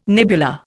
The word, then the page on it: nebula: Indian